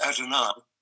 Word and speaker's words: Adonai: and it's generally in services is pronounced Adonai, although the ultra Orthodox, in fact, don't even use that.